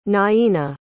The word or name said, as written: naina